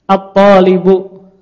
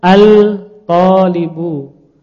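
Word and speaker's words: At-talibu (الطالب): At-talibu. Nah, salah kalau dibaca al-talibu.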